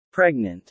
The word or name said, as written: pregnant